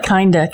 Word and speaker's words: Kinda: my name's Kinda Wilson. It's spelled like Kinda, K-I-N-D-A.